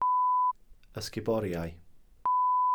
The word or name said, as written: ysguboriau